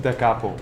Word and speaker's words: Da Capo: Da Capo. – Da Capo. For anybody who wants a nice, fine coffee, a time away from work, or to meet a friend, El Centro and Da Capo is the place to do it.